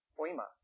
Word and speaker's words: poema: Poema is how it's pronounced.